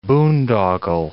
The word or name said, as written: boondoggle